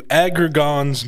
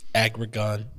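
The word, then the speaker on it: Aggron: Let's get into Agrigan's Mountain. – I'm so glad you pronounced that. Agrigan.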